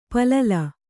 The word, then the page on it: ಪಲ್ಲಲ: pallala